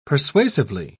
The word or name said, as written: persuasively